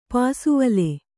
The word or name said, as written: ಪಾಸುವಲೆ